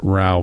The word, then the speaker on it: Rowe: I was lying in a hospital bed then, but I saw some videos of Sean Rowe, and he pronounces it Rao, by the way.